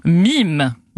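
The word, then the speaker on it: meme: Et en fait, pour être plus précis, le nom mème, qui se prononce en VO, attention, mime.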